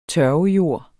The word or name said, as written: tørvejord